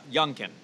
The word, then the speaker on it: Youngkin: Youngkin is how you say his name.